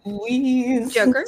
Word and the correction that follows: Luis: Joker, say it correctly. – Luis Guzman.